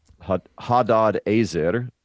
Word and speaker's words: Hadadezer: Hadadezer would be the proper pronunciation of it.